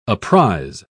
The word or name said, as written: apprise